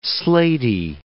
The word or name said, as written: slaty